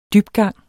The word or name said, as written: dybgang